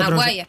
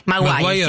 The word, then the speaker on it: Maguire: Sí, estás hablando de Toby Mac, no sé cómo se Maguire. – Pronuncia. – Maguire.